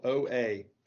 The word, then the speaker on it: Oa: It's spelled O-A, pronounced Oa.